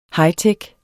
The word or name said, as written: hightech